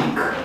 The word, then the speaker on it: hic: Și multe cuvinte cu formă scurtă, de exemplu pronumele demonstrativ aferent persoanei întâi, hic, care oricum se pronunța ic, pentru că ca și inițial nu îl pronunțau, probabil că se pronunța i la un moment dat și până la urmă nu s-a mai moștenit absolut deloc.